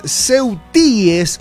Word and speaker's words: ceutíes: Del gentilicio formal de los habitantes de Ceuta es ceutíes, con acento en la I.